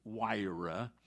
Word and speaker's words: Jireh: And there is a line in this song called Jireh. Jireh is the Hebrew word provider. I know there is no J. It's pronounced Wira in Hebrew, but the name of the song is Jireh, and in that song, there's a line in there that just haunts me.